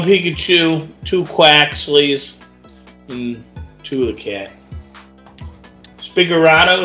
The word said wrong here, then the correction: Sprigatito: is that how you say its name? – Sprigatito? – Yeah.